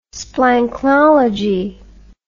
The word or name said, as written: splanchnology